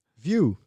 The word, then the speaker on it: Vue: Hoe spreek je dit uit? Vue? View. Vue, Vue. Yes.